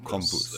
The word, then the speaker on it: Krampus: Krampus.